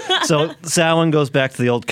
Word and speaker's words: Samhain: but you said happy Samhain? – What? It's actually pronounced Samhain.